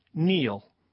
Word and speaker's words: kneel: K-N-E-E-L, Neil. – Raspberry, answer. – Silent letters. – Yes, silent letters. Raspberry is... – The silent S. – P. – Well, P. Sorry. – Silent P, right. Answer. – W. – W and Neil. – Okay. – The K, which was silent until I spelled it earlier in the clue.